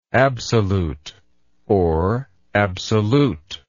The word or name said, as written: absoluteness